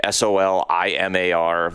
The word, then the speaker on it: Solimar: Um, hopefully, I'm pronouncing that right. S O L I M A R. I don't know any other way to pronounce it.